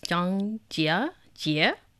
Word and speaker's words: Zhangjiajie: Well, one of the popular places or destinations amongst locals as well as foreigners is Zhangjiajie.